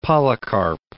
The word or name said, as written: Polycarp